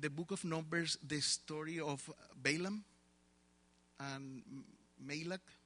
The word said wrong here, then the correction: Balak: But Balak was afraid of the Jews.